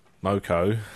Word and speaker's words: Moko: Moko. – Moko. Moko. Moko rhymes with Coco, not Moko rhymes with Toko.